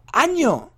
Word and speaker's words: año: feliz ano nuevo, ano nuevo, no, no se dice ano, no se escribe ano, se, se pronuncia año, feliz año nuevo y se escribe con ñ, ñ, ¿vale?